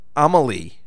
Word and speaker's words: Amelie: Amelie.